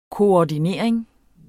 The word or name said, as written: koordinering